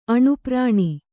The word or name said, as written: ಅಣುಪ್ರಾಣಿ